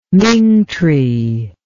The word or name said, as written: ming tree